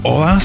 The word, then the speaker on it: hola: Hola se píše z H, ale pozor, Španělé H nikdy nevyslovují.